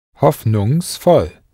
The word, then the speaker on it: Hoffnungsvoll: Be careful here when you try to say hopeful. An extra S slips in after Hoffnung for ease of pronunciation. So how would you say hopeful? – Hoffnungsvoll.